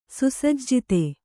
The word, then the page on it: ಸುಸಜ್ಜಿತ: Kannada